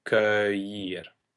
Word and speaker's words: cueillir: because the UE functions both as the hardening of the K device, but also as a combination EU that we pronounce as an OE in French. So cueillir.